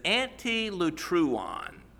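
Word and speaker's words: antilutron: Now, the word that is used here is antilutruon. Antilutruon, let me spell that for you. A-N-T-I-L-U-T-R-O-N. A-N-T-I-L-U-T-R-O-N.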